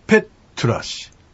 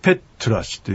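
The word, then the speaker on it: ペッㇳラシ: はい、ちょっとこの表記の方ですけども、とと書いてちっちゃいう、またはですね、とと書いてちっちゃい、丸を書いて実は表現したりしてるんですけども、これはとという発音なんですね。ですから、ペットラシ、ペットラシというふうに発音します。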